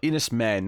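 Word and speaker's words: Enys Men: So Mark Jenkins, his next film is going to be the Cornichette horror film Ennis Man. Or maybe Ennis Man. Maybe it'll be Ennis Man. But I'll say, sorry, I butchered this title. Enos Men, not Man.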